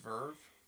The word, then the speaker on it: VRV: And it's VRV. I guess it's pronounced Verve.